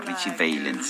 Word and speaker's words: Richie Valens: Richie Valens en españolizado. – Él, se, él, lo pronunciaba Richie Valens.